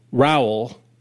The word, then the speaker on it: Rowell: Uh, Rainbow Rowell there did you go. It? Did I get it? I stuck yep. the landing. she always line. says rhyme with towel.